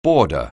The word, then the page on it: boarder: anglais